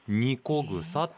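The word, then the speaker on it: 和草: あの和風の和に草と書いてニコグサって読むんです、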